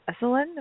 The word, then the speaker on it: Esalen: She is honored to be a co-founder of the annual Yoga, Meditation, and Recovery Conference at Esalen, am I saying that correctly? – Yeah.